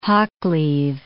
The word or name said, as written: Hoccleve